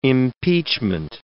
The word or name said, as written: impeachment